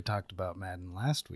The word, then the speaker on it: Madden: It rhymes with fadden. – that's the word you came up with. – I have been playing John Madden, the 20th edition.